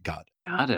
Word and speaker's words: Godot: Is that how you pronounce it? I always said Godot. – Every single production I've seen or heard of the play has the actors pronouncing it Godot.